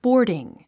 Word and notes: boarding: bɔ́ː r diŋ; ボーディン

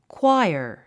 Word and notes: choir: kwáiə r; クワイアァ

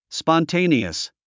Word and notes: spontaneous: spɑntéiniəs; スポンテェニアス

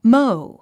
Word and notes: mow: móu; モォウ

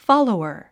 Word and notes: follower: fάlouər; フォロワァ

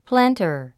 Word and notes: planter: plǽntə r; プランター

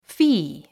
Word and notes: fee: fíː; フィー

